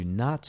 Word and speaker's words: and: I pronounced the word and, A-N-D, as N. This is an example of a reduced form. The vowel A in and is reduced to U or I. Either reduction is correct.